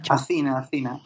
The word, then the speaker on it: Athena: Oye, para las personas que no, que no lo conozcan, esas tres ramas, o sea, Adina, Adina, Acina. Acina, Acina. Se escribe como Atena, A-T-H-I-N-A.